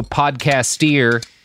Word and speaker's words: podcasteer: one of my favorite stand-up comedians and fellow podcasteer. Like musketeer. It's spelled like musketeer, but with podcast.